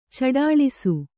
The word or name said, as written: ಚಡಾಳಿಸು